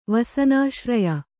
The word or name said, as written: ವಸನಾಶ್ರಯ